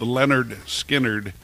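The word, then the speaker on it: Lynyrd Skynyrd: It's pronounced Leonard Skinner.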